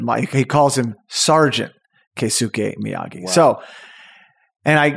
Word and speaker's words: Keisuke: and he is called Keisuke Miyagi. It's, you want to spell it? Because you have it right there, I believe. – Sure. That would be K-E-I-S-U-K-E.